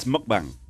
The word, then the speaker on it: mukbang: Do you know how to pronounce it? It's mukbang.